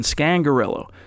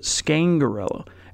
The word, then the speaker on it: Scangarello: because we were all saying Scangarello, but he pronounces it Scangarello.